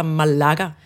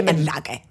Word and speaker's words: Málaga: Jeg ved ikke, hvordan man udtaler Malaga eller Malaga? Ej, ikke Malaga. Malaga.